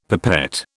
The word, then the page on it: pipette: British English